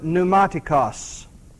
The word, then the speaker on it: pneumatikos: Now, the word spiritual there, you may want to take note, is the Greek word pneumatikos. Pneumatikos. Let me spell it for you. It's P-N-E-U-M-A-T-I-K-O-S. P-N-E-U-M-A-T-I-K-O-S.